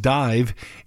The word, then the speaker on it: DIIV: New at Noon comes your way from the band Dive. It is spelled in all capital letters, D-I-I-V.